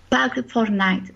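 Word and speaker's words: Park4Night: y después una app muy útil es Park Fortnite, o sea, se escribe Park 4 Night en inglés.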